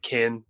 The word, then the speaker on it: ken: Again, I think it's K-E-N. It's pronounced like kin, but it's like Ken, like the Ken doll from Barbie.